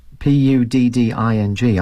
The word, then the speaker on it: pudding: It takes some that word pudding. I find difficult to pronounce because I want to say. Pudding, but the correct English pronunciation is pudding. Pudding, that's it. Pudding. And it's very difficult for me to say that because I'm used to saying pudding.